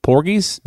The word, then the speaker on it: porgies: Porgies? That's how you say it.